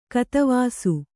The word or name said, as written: ಕತವಾಸು